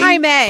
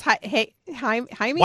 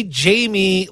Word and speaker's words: Jaime: I said Jaime. It's May, right? And it's Jaime. – Why don't you just say Jamie like it's spelled? J-A-I-M-E.